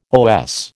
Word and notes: OS: American English

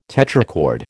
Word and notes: tetrachord: American English

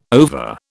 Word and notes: over: British English

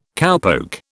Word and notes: cowpoke: British English

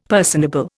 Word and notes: personable: Indian

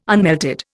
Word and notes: unmelted: Indian

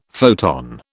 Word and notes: photon: British English

